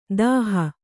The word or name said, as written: ದಹ